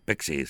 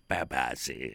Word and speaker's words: Pepsi: A ver, no se dice Pexi, se dice Pepsi.